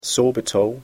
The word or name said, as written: sorbitol